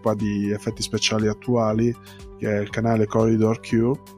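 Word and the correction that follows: Corridor Crew: Crew, scusate la pronuncia,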